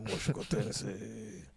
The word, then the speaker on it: Mushoku Tensei: You mean minus Makusho, Mushoku, whatever, however you pronounce it. – Like, Mushoku Tensei. – Yeah, yeah. – I always butcher the name, bro. – Mushoku Tensei.